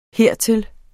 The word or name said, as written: hertil